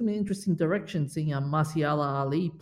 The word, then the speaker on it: Mahershala Ali: Did you actually pronounce his name right? – Marsha. – I don't think. I was going to say, I don't think it's actually Marsha Ali. – Mahershala Ali.